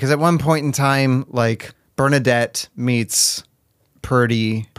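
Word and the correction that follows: Prudie: Prudy. – I've been saying it wrong.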